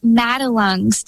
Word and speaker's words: Madelung's: It's pronounced Madelung's deformity.